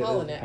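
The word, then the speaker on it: Palahniuk: how do you say Polinick.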